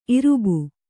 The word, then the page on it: ಇರುಬೆ: irube